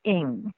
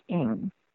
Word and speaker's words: Ng: We pronounce it Ng, and that's my family. It's actually a very popular name in Hong Kong. In Hong Kong, it's pronounced Ng. But a lot of Americans have problems pronouncing that because it feels like they're having they've forgotten something. So we made it easy when my father and my mother came to this country, and they just told people to pronounce it as if there was an I in front of it. So it sounds like ing.